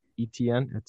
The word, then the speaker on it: Etienne: Especially when you get in these later rounds, because running backs left were like Travis Etienne. I still can't say it right, and I'm not going to all year. – Etienne.